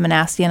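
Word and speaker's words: Minassian: Yeah, so just type in Melissa Manassian and I should come up.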